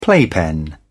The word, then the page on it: playpen: British English